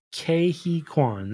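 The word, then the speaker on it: Ke Huy Quan: Kei-Hee Kwan.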